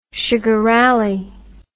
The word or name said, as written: sugarallie